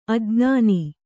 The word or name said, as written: ಅಜ್ಞಾನಿ